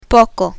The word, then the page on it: poco: Spanish